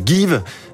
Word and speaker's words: Geev: Vous êtes le cofondateur de Give, euh, qui s'écrit G-E-E-V.